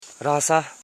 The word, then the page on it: ransa: ran.sa; Koro